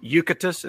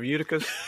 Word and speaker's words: Eutychus: I'm sorry, I've got mispronunciations on the brain because the preacher at my church this morning kept calling him Eucatus instead of Eutychus, the guy who fell out of the window.